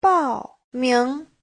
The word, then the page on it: 报名: bao4ming2